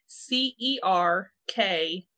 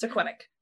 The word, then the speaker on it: Serkvenick: So during this time, there was a couple by the name of John and Joanna Serquenic. And I believe that is the correct way to say it. It is spelled Serkvenick. So at first I'm like, Serkvenic? No. Believe it is Serquenic.